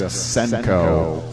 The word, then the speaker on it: Cenco: Now, it's spelled C-E-N-C-O, and if that was an Italian record label, it would be called Cenco. But I think it's just Senko, probably the names of two of the owners of the record label, I'm guessing.